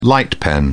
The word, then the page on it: light pen: British English